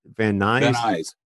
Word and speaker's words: Van Nuys: Brad Van Nuys? – Van Nuys.